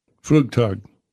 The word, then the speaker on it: Flugtag: Is it Flugtag? How do you pronounce that? What's that? – Flugtag.